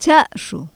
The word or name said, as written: cha7zhu